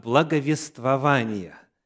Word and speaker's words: благовествования: Ударение, к сожалению, именно здесь стоит. «От благовествования», гласит орфографический словарь.